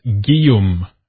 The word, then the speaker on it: Guillaume: Anyway, today we are speaking with Guillaume Dorochy, and I do mispronounce his name a few times early in the show. It's Guillaume. Anyway, he is a bilingual French political writer and historian.